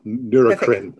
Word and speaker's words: Neurocrine: Du bist in San Diego, weil du bist der Executive Medical Director bei Neurocrine Bioscience. Habe ich das richtig ausgesprochen? – Ja, genau. Neurocrine.